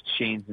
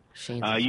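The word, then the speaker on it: Shane's: S-H-A-N-E, apostrophe S, Chain's Inspiration. – Chain's.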